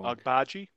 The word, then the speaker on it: Agbaji: O'Shea, I always get that the name wrong. – Agbaji is his last name. – Agbaji, yeah.